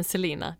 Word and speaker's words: Celaena: Men Selena, det stavas ju C-E-L-A-E-N-A.